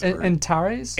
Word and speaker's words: Antares: Why do I keep wanting to say Astaris? – Antares? – Antares, yeah. Antares.